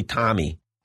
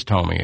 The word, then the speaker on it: Tomi: Because I always say Tommy. – Tommy. Yeah, I think it's Tomi is the way it is.